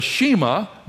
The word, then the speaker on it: Shema: It's spelled S-H-E-M-A. Say that word out loud with me. The Shema.